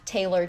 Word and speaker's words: Taeler: So it is at Taylor, T-A-E-L-E-R, Fit, F-I-T.